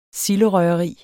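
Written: silderøgeri

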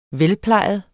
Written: velplejet

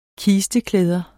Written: kisteklæder

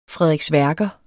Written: frederiksværker

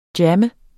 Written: jammer

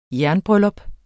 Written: jernbryllup

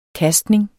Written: kastning

